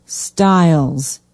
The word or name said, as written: stiles